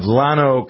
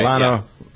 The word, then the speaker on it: Llano: A judge has ruled in favor of residents of Llano County. I think that's how you pronounce it, right? L-L-A-N-O? – That's right. – Llano County?